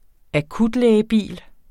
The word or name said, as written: akutlægebil